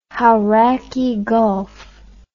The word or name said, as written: Hauraki Gulf